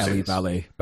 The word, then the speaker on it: Eli Valley: I believe his name is pronounced Ellie Valley, by the way.